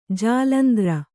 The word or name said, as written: ಜಾಲಂದ್ರ